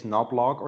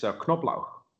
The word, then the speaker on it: Knoblauch: Well, what you should pronounce it like in German, and it's Knoblauch. – Say that again. Knoblauch. – Knob. And then Knoblauch. Okay. – Knoblauch. So you have to pronounce the K in front of the N.